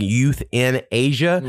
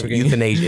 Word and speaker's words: euthanasia: Because I know people say euthanasia and you think youth in Asia, but it's not that. It like spelled like E-U-T-H, euthanasia. It's like one word.